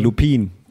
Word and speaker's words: Lupin: altså, der, bliver, der, der staves L-U-P-I-N, men det udtales jo Lupin,